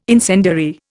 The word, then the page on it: incendiary: Indian